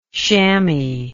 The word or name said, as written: shammy